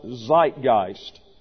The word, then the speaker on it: Zeitgeist: It is an English word taken from the German, pronounced Zeitgeist, Zeitgeist.